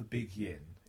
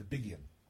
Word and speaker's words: big yin: I discovered recently that us soft southerners mispronounced that. It's not the big yin, it's the big yin.